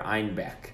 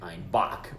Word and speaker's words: Einbeck: So, in the north, they pronounce the beer Einbeck. And now, in the south, the Bavarians misconstrued that to sound like Einbock, which means billy goat.